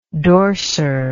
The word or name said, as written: dorser